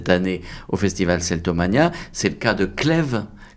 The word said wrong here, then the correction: Cléo: Comment ça se prononce ? – Cléo. – Ah oui, ça doit s'appeler Cléo.